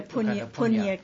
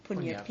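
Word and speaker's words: puñña: Look under Punya Kiriawatu. Punya, P-U-N-N-A. – How do you spell it? – Punya. – P-U-N-N-A.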